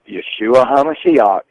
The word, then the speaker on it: Yeshua HaMashiach: This is a signal that the return of Yeshua HaMashiach, is how you say it in Hebrew, is close.